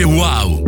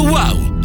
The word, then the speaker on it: UAU: Si scrive UAU, si legge wow. Auto wow. Lasciati stupire.